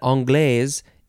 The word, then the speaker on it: anglaise: And then this one is anglaise. Creme anglaise. And you can pronounce that a few ways. Anglaise, anglaise. It is spelled, that second word is spelled A-N-G-L-A-I-S-E, noun from 1975.